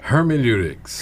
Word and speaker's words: hermeneutics: You know, we will talk about the, and what is it, hermeneutics? How do you say it? – Hermeneutics. – Hermeneutics, yes. Yep.